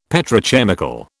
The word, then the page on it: Petrochemical: British English